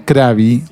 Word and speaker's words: Corabi: En realidad, para sintetizar la historia de John Krabby, que se escribe John Corabi, pero se pronuncia Krabby o algo así, tipo John Krabby.